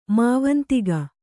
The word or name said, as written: ಮಾವಂತಿಗ